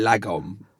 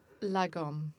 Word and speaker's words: Lagom: La Gomme. – Yeah, it should be La, like L-A-H, and then Gomme, La Gomme.